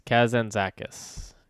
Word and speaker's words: Kazantzakis: No, it's Kazantzakis. Kazantzakis.